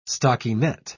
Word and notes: stockinet: British English

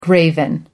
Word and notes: graven image: American English

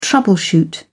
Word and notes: troubleshoot: British English

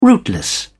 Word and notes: rootless: British English

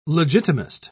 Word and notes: legitimist: British English

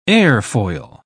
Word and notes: airfoil: British English

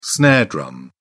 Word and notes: snare drum: British English

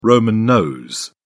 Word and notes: roman nose: British English

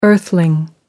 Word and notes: earthling: American English

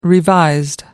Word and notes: revised: American English

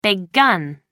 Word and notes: big gun: British English

